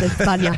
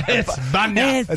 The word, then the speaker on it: España: You know, it's actually pronounced España. – España.